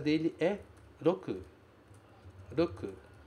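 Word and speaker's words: roku (六): Cuidado com esse R, porque a pronúncia do R no japonês é sempre rarirurero. Roku. Roku. Se você pronunciar roku, o japonês não vai entender o que você está dizendo.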